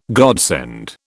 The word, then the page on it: godsend: British English